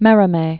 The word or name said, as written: Prosper Merimee